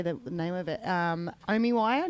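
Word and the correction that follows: OmmWriter: Do you pronounce it two M's? – OmWriter.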